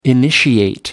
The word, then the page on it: initiate: American English